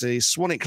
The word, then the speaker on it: Swanwick: So there you go, that's the Brickworks Museum in, I will now say this correctly, I think I said that correctly this time. That's Swanwick Lane, Swanwick, Burlesdon, Southampton, SO317GW.